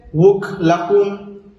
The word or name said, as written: wuklajun